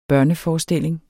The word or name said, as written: børneforestilling